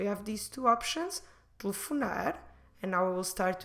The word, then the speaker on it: telefonar: And the examples I want to teach you today, or to show you today, we'll use the verb telefonar. Telefonar means to call on the phone. Telefonar. Telefonar. Como se escreve telefonar? T-E-L-E-F-O-N-A-R.